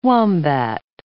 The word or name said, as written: wombat